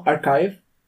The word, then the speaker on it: arXiv: So, you know, archive.